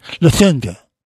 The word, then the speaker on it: Lufthansa: Lufthansa.